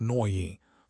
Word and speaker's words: Nooyi: Indra Nooyi, pronounced Nooyi, famously did this at PepsiCo.